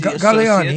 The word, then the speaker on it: Galliani: Galliani. He's Italian. I know how to say it.